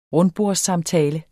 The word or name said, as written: rundbordssamtale